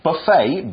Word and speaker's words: buffet: A buffet, B-U-F-F-E-T. You don't pronounce the T, it's mm-hmm. – buffet, okay? B-U-F-F-E-T, but the T is silent.